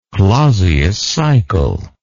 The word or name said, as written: clausius cycle